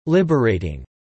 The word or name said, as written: liberating